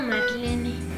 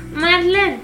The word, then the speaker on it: Marlene: Pero me acostumbro a decirlo con la E. Porque la gente es muy ignorante. Y siempre la pronunciaban lo mismo en la escuela. Marlene. – Es alemán. Sí se pronuncia la E.